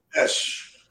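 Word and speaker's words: Esch: I don't know how to say his last name. – Esh. – Is Esh? Oh, it's Esh. Okay.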